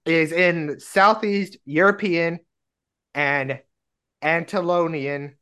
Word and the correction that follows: Anatolian: folklore. I think I'm pronouncing that right. – Anatolian? – Yes, that one.